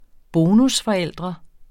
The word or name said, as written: bonusforældre